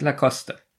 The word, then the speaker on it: Lacoste: Das heißt nämlich Lacoste.